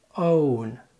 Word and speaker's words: own: اسمع كده own on own on own on. طيب ده كان النطق الامريكي. تعال بقى انطقهم لك بريطاني بنفس الترتيب وبرضو هتلاقي ان OWN ممدودة و ON قصيرة.